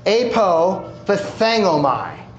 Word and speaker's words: apophthegmomai: The Greek word for utterance is pronounced this way, apophthegmomai. Apophthegmomai.